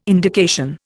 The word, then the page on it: indication: Indian